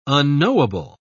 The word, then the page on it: unknowable: British English